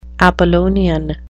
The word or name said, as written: apollonian